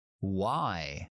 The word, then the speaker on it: Y: Se pronuncia exactamente igual que el pronombre interrogativo. ¿Por qué? Why. Why. Why. Sería como una W, w y luego el diptongo ahí, ahí, why, why.